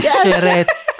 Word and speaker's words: Ceretti: Så här uttalar man hennes namn. – Vittoria Ceretti. Vittoria Ceretti. – Ceretti.